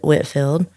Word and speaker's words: Whitefield: In the South, we often pronounce words and names differently than they're spelled, such as we have a place nearby called, it's spelled Whitefield, but we pronounce it Whitfield.